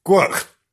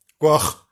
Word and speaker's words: Gwyrch: Well, now, into how do you pronounce this? G-W-R-Y-C-H. Gwyrch. Gwyrch. Gwyrch. Gwyrch. Something like that.